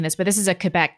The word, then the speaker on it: Quebec: There was a shocking clip this week out of Quebec or Quebec. Is that how they say it in French Canada? Quebec. – It is.